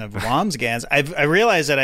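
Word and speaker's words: Wambsgans: Yeah, I said Wamsgams, and it's Wamsgans. – It's Burger. Oh, is it? Okay. – No, there's an N or an M. It's hard to say. It's just two syllables.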